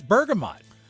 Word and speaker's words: bergamot: Italian, now what's the correct pronunciation? Bergamot? – I think it's bergamot. – Bergamot, Italian bergamot, B-E-R-G-A-M-O-T. – Got it right here.